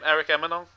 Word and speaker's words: Emanon: So where did the name Eric Emanon come from?